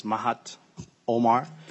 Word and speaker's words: Mahad Omar: My name is Mahat Omar, M-A-H-A-D-O-M-A-R.